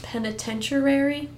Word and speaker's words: penitentiary: Penitentiary? I said that right, right? – Yeah, you did. – Good job, me. I practiced it all day. – You used, like, different vowels, but yeah, you broke it up.